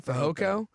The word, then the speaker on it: Fehoko: Fehoko.